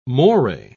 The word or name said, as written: moray